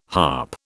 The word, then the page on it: harp: British English